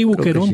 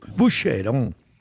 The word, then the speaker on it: Boucheron: Sí, Boucheron, sí. Pues, como el Boquerón, acá, no, en el... No así, hombre, eso. Boucheron. – Boucheron. – Suena en francés. Boucheron.